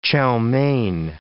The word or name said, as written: chow mein